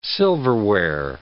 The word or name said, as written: silverware